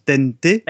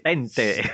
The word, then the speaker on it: Tente: Alors, il faut savoir aussi qu'à l'époque, ça c'est un jouet que j'ai connu, il y a une marque espagnole qui s'écrit Tente, mais qui se prononce Tente. – Tente.